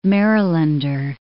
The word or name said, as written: Marylander